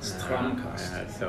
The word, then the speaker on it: Stramkals: Stramkals.